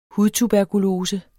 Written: hudtuberkulose

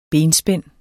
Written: benspænd

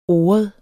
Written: oret

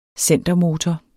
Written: centermotor

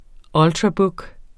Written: ultrabook®

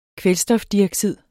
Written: kvælstofdioxid

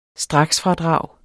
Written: straksfradrag

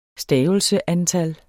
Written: stavelseantal

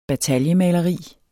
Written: bataljemaleri

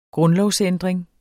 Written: grundlovsændring

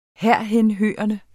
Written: herhenhørende

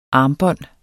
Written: armbånd

